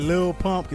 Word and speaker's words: Lil Pump: Yeah, featuring Little Pump. – Yes, Little. Or my bad, Lil Pump, because everything is spelled L I L.